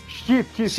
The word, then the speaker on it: Chiefs: Certa a pronúncia, gente? Chiefs. Chiefs.